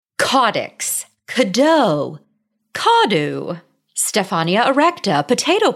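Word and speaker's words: caudex: There are so many different ways to refer to caudix plants, which I've recently learned caudix is the correct way to pronounce it.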